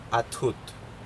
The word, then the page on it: athut: Aneityum